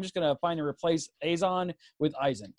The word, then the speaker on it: Eizan: They plainly pronounce it in Japanese as Eizan.